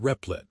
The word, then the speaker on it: Replit: Replit, pronounced Replit, a software development platform, has transitioned to a self-driving model.